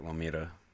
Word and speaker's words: Lomita: Lomita, California. – Yeah, that's how I say it. Lomita.